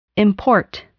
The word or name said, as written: import